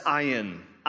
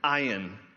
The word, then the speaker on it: ayin: The Hebrew letter of the alphabet is pronounced ayin, ayin.